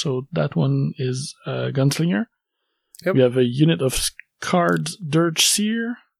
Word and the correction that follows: Shard: Shard. Yeah, the Shard. – Shard. – Yeah. – Okay. Yeah, I shouldn't pronounce the name. – It's Shard. That's all.